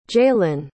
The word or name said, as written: jaelynn